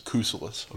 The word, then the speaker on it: Kousoulas: Kousoulas.